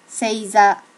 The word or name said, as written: seiza